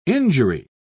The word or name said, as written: injury